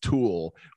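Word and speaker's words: Thule: It's T-H-U-L-E. And somebody told me it's pronounced Tool, it's those really great bike racks.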